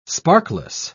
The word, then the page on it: sparkless: British English